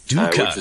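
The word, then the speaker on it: dukkah: Duka, that's how you say it, right.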